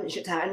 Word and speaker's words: JaTaun: As far as Facebook, I'm Jatan Noel.